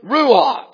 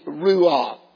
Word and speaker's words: ruah: Now the other word that is real similar to that is the word ruah. It's spelled R-U-W-A, but transliterated through the English pronunciation, you'll find it to be ruah.